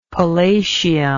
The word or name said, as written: palatium